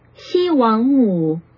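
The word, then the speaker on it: Xi Wang Mu (西王母): So here's her name. – Shi Wang Mu. – Oh, Mu. It sounds like it's going down. – Xi Wang Mu. – Mu. Yeah. It's not Mu.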